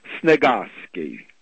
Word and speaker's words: Sniegoski: S-N-I-E-G-O-S-K-I, and it's pronounced, we pronounce it, but you can leave out the E-type sound, Sniegalski.